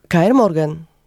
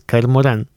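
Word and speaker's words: Kaer Morhen: Moim zdaniem to jest K.R. Moran. – Nie. Przykro mi, ale nie, to jest K.R. Morgan. No ale każdy sobie no, tak wymawia bardziej jak gardłowo, tak. K.R. Moran. – Mhm, no dobrze. – Bo to jednak z Elfickiego.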